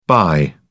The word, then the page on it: bi: British English